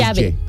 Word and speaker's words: llave: El doble L, ¿cómo se dice? – Ya, más marcado. Ya. Ya. – Like, por ejemplo, calle. – Llave.